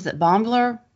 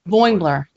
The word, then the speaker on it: Boimler: And then also, Bombler? I can never pronounce his name. – Boimler.